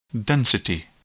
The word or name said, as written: density